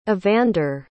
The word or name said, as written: evander